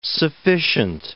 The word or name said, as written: sufficient